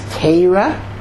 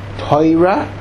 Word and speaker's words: Torah: There is Teira, which is the Litvisha pronunciation. There is Toira, which is the Polish and Russian pronunciation.